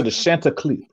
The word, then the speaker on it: Chanticleers: They was hot, but anyway, Shout out to the Chanticleers.